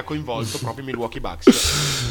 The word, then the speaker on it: Bucks: E, ehm, a proposito di BUCS, che si pronuncia Bucks, sì, anche perché Bucks si scrive in maniera diversa.